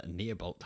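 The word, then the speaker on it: Neibolt: His friends gather their artifacts and they join him at the Neobolt house.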